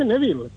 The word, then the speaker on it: Neville: Se dice Neville, ¿por qué todo el mundo dice? – No, Conino se dice.